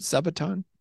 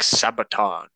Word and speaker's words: Sabaton: Sabaton. – I think Sabaton.